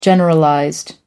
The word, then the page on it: generalized: American English